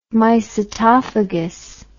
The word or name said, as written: mycetophagous